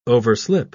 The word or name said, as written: overslip